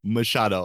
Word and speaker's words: Machado: Machado.